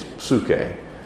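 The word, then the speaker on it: psuche (ψυχή): The New Testament soul, people write it, when they write it in English, they write it P-S-Y-C-H-E. And it looks like it's saying psyche. And it is where we get the English word psyche. But it's, people pronounce it in Greek, it's psuche.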